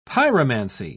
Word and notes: pyromancy: British English

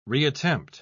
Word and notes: reattempt: British English

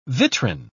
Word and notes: vitrine: British English

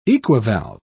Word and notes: equivalve: British English

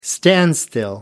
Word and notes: standstill: American English

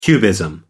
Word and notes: cubism: British English

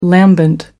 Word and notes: lambent: American English

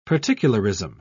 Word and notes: particularism: British English

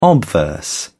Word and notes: obverse: British English

